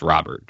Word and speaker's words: Robert: it's pronounced Robert, just like, you know, like the first name, American first name, Robert.